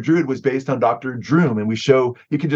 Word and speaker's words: Droom: and he's rolled out first as Dr. Droom, not Dr. Doom, but with a rhyming with broom, Dr. Droom.